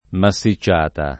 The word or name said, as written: massicciata